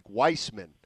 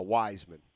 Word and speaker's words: Wiseman: You know what's funny is we went back and forth out here for a while because in the official NBA pronunciation, it's like Weissman. – Weissman. – Yeah, but apparently Raymond Ritter went up to Wiseman and Wiseman said no, Wiseman. It's Wiseman.